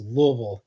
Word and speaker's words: Louisville: So I can tell you it's supposed to be pronounced Louisville. Like, if you put too much, if your tongue is working too hard, you're saying it wrong.